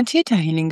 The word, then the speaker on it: Theta Healing: Theta Healing, spricht man das eigentlich so? Weil es ja ein TH am Anfang ist, könnte man ja auch Theta Healing sagen. – Es wird immer Theta Healing gesagt.